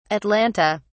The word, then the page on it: atlanta: British English